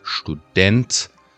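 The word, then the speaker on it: Student: یا اینتوناسیون، مثلا در واژه دانشجو به زبان آلمانی شتودنت سیلاب دو میره بالا، خیلی مهمه که سیلاب دو رو بریم بالا،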